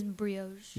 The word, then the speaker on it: brioche: It's brioche.